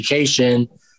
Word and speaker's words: Kashian: uh, but the fact that cation is the actual pronunciation of Jackie Kashian uh, is absolutely brilliant.